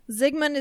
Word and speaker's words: Zygmunt: Zygmunt is the correct pronunciation.